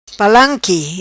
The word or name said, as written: palanqui